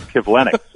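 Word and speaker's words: Kivlenieks: It's Kivlenix, and it's kind of like you put the wrong emphasis on the wrong syllable.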